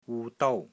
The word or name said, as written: WU6 DOU1